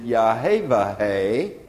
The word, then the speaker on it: Yahweh: And so if you would pronounce the letters, you would pronounce it Yahweh. And in English, we turn that around and we think it's pronounced Yehovah.